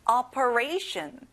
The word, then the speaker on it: operation: 수술, operation. 친구들, ray에 강세를 넣어 보세요. Operation. Very good.